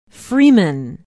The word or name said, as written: Freeman